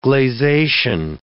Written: gleization

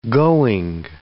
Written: going

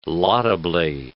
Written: laudably

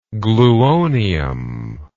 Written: gluonium

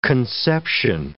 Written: conception

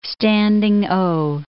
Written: standing O

